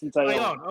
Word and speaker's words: Taillon: So you got Talon, how do you say his name? – Jameson Taillon. – Okay, so you got Taillon tonight, then you got Cole, and then you got Cortez.